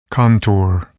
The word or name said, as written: contour